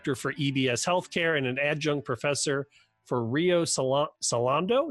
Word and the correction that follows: Rio Salado: Did I say that right? – Rio Salado College, also a clinical associate professor at Arizona State University.